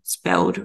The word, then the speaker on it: SPELD: Yeah, so that South Australian spelled readers, and that's spelled SPELD. They're a great resource.